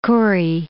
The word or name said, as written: Kure Atoll